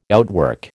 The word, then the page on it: outwork: American English